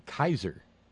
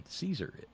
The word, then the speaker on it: Caesar: I mean, as far up as 1914, up to 1918, you know, the German term Kaiser, that's actually a closer to correct pronunciation of C-A-E-S-E-R than Caesar is.